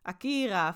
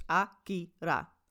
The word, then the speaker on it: Akira: Dus waarin wij van nature in het Nederlands Akira vaak willen zeggen, is in het Japans is het, va is het vaak de, de lettergrepen zijn of kort of lang. Dus het is Akira allemaal kort, kort, kort. Dus het is heel plat eigenlijk.